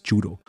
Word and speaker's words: Judo: ist nicht nur sein Papa, der Judo sagt, sondern in Österreich heißt es Judo. Mhm. Also die, die korrekte. – Hat er mir auch gesagt, ja. ja korrekte Aussprache ist Judo in Österreich.